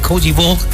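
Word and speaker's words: Côte d'Ivoire: Ya hay alineaciones por parte de Alemania ante Cody Vogt. Así se pronuncia en François.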